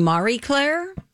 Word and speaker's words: Marie Claire: I heard something else, that Marie Claire is actually Marie Claire.